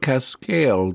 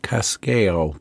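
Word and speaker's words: Cascajal: Think that's about right. Cascale. The J is usually silent in Mexican and Spanish, so Cascale block.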